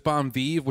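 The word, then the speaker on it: Bon & Viv: How do you say this? Bon Vive? – There's Bon Vive, which is the original.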